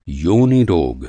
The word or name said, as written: योनि